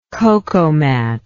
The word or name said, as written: cocomat